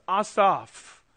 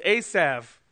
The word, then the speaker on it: Asaph: His name uh, in, in, the, in, the, Hebrew is pronounced, is pronounced Asaph. Uh, but for the sake of familiarity, we will use the English pronunciation today, which is, is Asav.